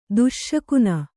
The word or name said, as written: ದೋಷಘ್ನ